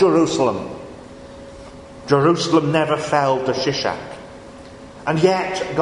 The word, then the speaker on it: Shishak: The Pharaoh Shishak, the Egyptian pronunciation is more like Shoshank, but you know how it goes when you take a word from one language into another. It can sometimes do some very interesting things with the vowels.